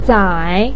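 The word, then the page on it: zai: tone 3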